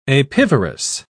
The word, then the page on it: apivorous: British English